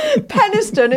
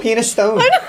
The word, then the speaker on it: Peniston: Peniston is pronounced. – Oh, is it? – There we go. Oh, Peniston. – It's pronounced. – It is actually Peniston. – Spelled penis stone.